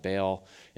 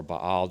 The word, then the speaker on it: Baal: But sometimes there were other gods that were more popular, and they chased after them, like the god Baal, or Baal, depending on how you pronounce it, instead of the Yahweh God.